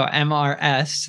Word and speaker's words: Mrs.: So MRS, that's pronounced Mrs., and that's a married woman, and Miss is a single woman.